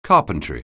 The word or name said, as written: carpentry